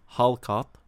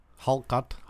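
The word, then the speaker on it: hákarl: It's spelled H A with a little dash at the top, whatever that's called. K A R L. And it's called Hallkatt. – Hallkatt. – Hallkatt.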